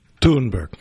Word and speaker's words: Thunberg: So Greta Thunberg, please pronounce it. – Thunberg.